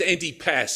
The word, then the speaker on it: Antipas: Herod Antipasta, is that right? Is that right? Sorry, it's Antipas. Sorry, Antipas. A-N-T-I-P-A-S.